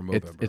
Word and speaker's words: Movember: Would you say Movember or Movember? – It's literally just pronounced as November with the N replaced with an M.